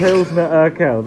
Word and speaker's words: Pilsner Urquell: But we're trying Elsnepil. Oh, God, now I've butchered it already. – Double butchering. – Say butcher one more time. – Meat cleave. – Elsner Pilsner? – Pilsner Urquell, I think. – Pilsner Urquell, there we go.